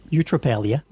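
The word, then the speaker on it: eutrapelia: Eutropelia. Did I get that right? – Eutropelia. – Eutropelia.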